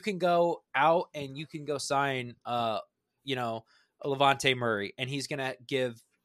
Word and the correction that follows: Latavius Murray: I just said that wrong. Man, that's embarrassing. Latavius Murray.